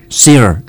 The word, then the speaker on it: Cyr: it's spelled C-Y-R, what is it? – Seer. – Yeah. What's that, the Seer wheel? – Seer wheel, it's a person, his name is Seer, and the wheel, it's coming from his name.